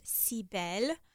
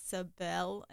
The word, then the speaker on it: Cibelle: And I remember I was so used to being called in French Cibelle. – Yeah. – And then my name here, they pronounced it Cibelle. And I was like, that's not my name.